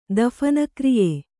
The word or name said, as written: ದಫನಕ್ರಿಯೆ